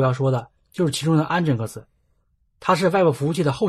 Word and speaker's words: Nginx: 在这里面，我必须要说一下 Nginx 的正确发音，它应该读成 Nginx，但我个人感觉 X 念起来太拗口，还是比较倾向于读作 Nginx，这也与 Unix、 Linux 的发音一致。